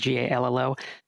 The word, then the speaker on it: Gallo: I think it's how you pronounce it, is Gallo.